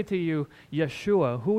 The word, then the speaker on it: Yeshua: The letter J, Justin or Joshua and all that, that's a gift to us from the English language. The way you would pronounce this is Yeshua.